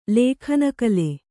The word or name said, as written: ಲಗ್ನಕಾಲ